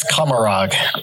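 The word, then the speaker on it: Commorragh: I didn't even know what Komora was till just now. – Embarrassing. We'll get to that later. Fresh out of the fight pits. – It's Komorog. It's Komorog. Say it right, please.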